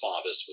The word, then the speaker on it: Favis: And also, my name on Twitter is at Steve, S-T-E-V, and my last name is pronounced Favis, but it looks like Favis or Favis, F-A-V-I-S, at Steve, F-A-V-I-S, or at Steve Favis.